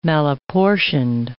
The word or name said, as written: malapportioned